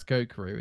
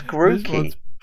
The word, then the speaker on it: Grookey: The little monkey, that's Gokuru, isn't it? And then... this little one is... – Is that his name? Gokuru? Oh. That's definitely not how you say it. No, it's Grookey.